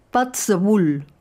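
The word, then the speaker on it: Puirt a Beul: La misma idea, pero en gaélico, una lengua coexistente con el inglés en Escocia, Patzabul, que se escribe Puit a Beul, melodías de boca.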